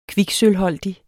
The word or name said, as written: kviksølvholdig